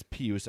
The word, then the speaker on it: Pius: Or Pius. Everyone says it's Pius. I'm going to have trouble getting used to that. – Pius, and we've been saying it wrong the entire time.